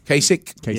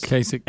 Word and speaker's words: Kasich: And then there's this weird do you say his name Kasich? – Kasich. – Yes. Kasich. Hey, don't use the H. Just say like it's not there.